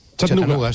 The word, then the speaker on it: Chattanooga: Chattanooga.